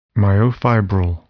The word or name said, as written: myofibril